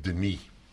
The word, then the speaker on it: Denis: One N is the French way, and it's pronounced Denis.